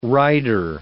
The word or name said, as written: rider